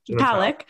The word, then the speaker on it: Palek: Pavlik, how do you say her last name? – Jenna Palik.